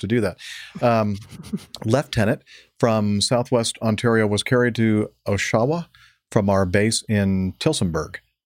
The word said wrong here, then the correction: Oshawa: Oshawa. I should have known better. Thank you, Liz. – Emphasis on the wrong syllable.